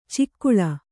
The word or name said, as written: ಚಕ್ಕಳೆ